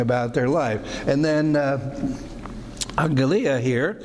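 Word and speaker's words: angelia (ἀγγελία): And so in first year Greek, they say usually you pronounce a double G as an NG, because it would be hard to say angelia. And so rather than stumbling over that extra G, they say pronounce the first G as an N. So we say angelia, and so we have A-N-G-L-E. E-I-A, in which we get, obviously, angel.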